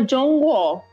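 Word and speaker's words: John Guo (中国): Eu ficou ressoando, e aí um tempo depois, na aula de chinês, eu fui aprender como é que falava China em chinês. E aí se chama John Guo.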